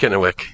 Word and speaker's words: Kennewick: Is that how you pronounce that? – Actually, yes, it is pronounced Kennewick.